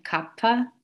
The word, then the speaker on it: kapha: So there's vata, pitta, and kapha.